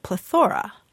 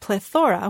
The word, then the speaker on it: plethora: But it looks like back in 1731, there was a dictionary that did pronounce it plethora. – Really? – Yes. And the reason is that it comes from the ancient Greek, plethora, which means fullness.